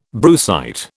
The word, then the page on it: brucite: British English